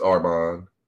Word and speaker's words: Armin: Yeah, it's Armin. – Okay, Armin. – Like Armin, okay. Armin with an R instead of an L.